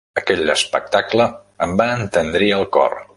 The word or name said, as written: entendrir